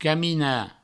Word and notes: caminar: provençal; camina

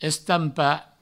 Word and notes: estampar: Provençal; estampa

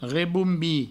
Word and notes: rebombir: reboumbi

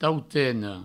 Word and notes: tautena: provençal; touteno

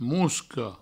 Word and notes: mosca: Provençal; mousco

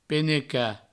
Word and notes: penecar: Provençal; peneca

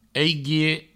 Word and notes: aiguier: eiguie

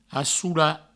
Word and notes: assolar: assoula

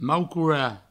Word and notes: maucorar: Provençal; maucoura

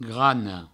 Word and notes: grana: grano; Provençal

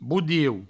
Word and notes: bodieu: boudieu

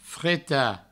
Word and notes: fretar: freta

